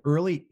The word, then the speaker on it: Earley: My email is letter C for Chris, followed by my last name, Early, EARLEY at Early.